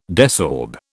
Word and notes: desorb: British English

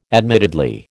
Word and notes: admittedly: American English

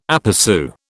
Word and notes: aperÇu: British English

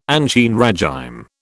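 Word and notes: ancien régime: British English

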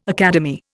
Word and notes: academy: Indian

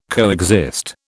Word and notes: coexist: British English

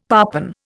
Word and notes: parpen: Indian

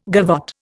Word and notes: gavotte: Indian

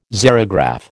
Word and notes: xerograph: American English

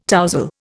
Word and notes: tousle: Indian

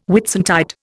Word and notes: Whitsuntide: Indian

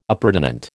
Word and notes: appurtenant: American English

